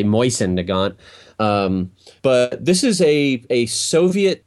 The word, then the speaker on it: Mosin Nagant: And it is actually impossible to pronounce this name correctly in any one language, but the correct pronunciation would be Mosin Nagant.